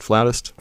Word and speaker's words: flutist: Your wife is a, how do you say it, flutist, flautist? – Flutist, yes.